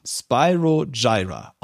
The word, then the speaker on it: Spyro Gyra: Spyro Gyra wird geschrieben S-P-Y-R-O, Spyro, und dann kommt ein neues Wort, G-Y-R-A, Gyra, Spyro Gyra, ausgesprochen.